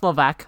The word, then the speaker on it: Levesque: It's actually pronounced Hazel-ek. – I didn't get the joke. – Her last name was Levesque. – I thought it was Levesque. – No, it's Levesque. That's how you pronounce it. – Oh, is the S silent? – Yes.